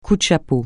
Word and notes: coup de chapeau: francais

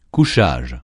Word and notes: couchage: francais

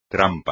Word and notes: trampa: espagnol

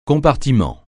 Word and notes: compartiment: francais

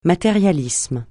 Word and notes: matérialisme: francais

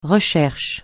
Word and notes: recherche: francais

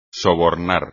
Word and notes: sobornar: espagnol

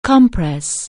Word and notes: compress: anglais

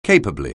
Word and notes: capably: anglais

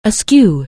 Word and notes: askew: anglais

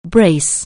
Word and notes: brace: anglais

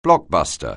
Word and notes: blockbuster: anglais

